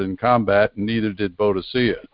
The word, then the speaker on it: Boadicea: Boadicea. Some people pronounce it Boadica, but you know, a lot of people know it as Boadicea.